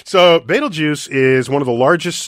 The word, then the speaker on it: Betelgeuse: Betelgeuse, and that's how you pronounce it, Betelgeuse.